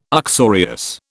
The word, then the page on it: uxorious: British English